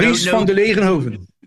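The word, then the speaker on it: Dries Van Langenhove: You've got to support people like Dries Vanderlegenhoven. I'm sorry. I'm trying to pronounce it. – Dries Vanderlegenhoven. – There you go.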